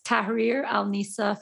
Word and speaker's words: Tahrir Al Nisa: It, can you pronounce it for me so I don't massacre how it's pronounced? – Yes, it's on hold right now. It's Tahrir Al Nisa Foundation.